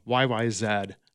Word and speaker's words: YYZ: YYZ. That's how Rush themselves actually pronounce it, and that's how the rest of the world pronounces it, not ZZ.